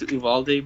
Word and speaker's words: Uvalde: that leaked recently about the let me see if I'm saying it right, of Olvulde, how do I say that? Sorry, dude. Like the Texas elementary shooting. – Yeah. – Olvulde? What is it? – I think it's Uvalde, but whatever.